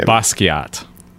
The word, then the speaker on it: Basquiat: Basquiat. – Basquiat.